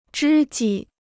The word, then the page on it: 知己: zhī jǐ